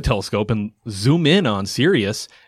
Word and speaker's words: Sirius: Yeah, Sirius.